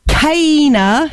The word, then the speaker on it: Kana: Kana, who I pronounced as K-neck earlier, because the K, the R looks like a K, so I do apologise for that, but it is a new band, so yeah.